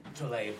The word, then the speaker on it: Tlaib: And Tlaib, is that how you pronounce her name? – Tlaib. – Yeah, Rashida. Tlaib, yeah.